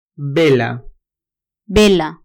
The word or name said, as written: vela